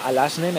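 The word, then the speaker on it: Alazne: No me acuerdo del apellido, pero se llama Alasne, me ha dicho que lo escriba con Z, pero se pronuncia con S.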